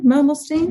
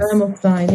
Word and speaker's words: Mermelstein: Mermelstein. Mermelstein. – Yes. – Mermelstein.